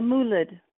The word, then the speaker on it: mulid: So today we are talking about something that we actually touched on a little bit in JTE 1, which were Egyptian molids. And I'm pretty sure I'm not pronouncing that properly. So would you say that for me? – A mulid. – Mulid. Thank you. – Mulid, yeah.